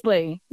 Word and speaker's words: Risley: Risley. Yeah, it's Risley, like the bear without the G.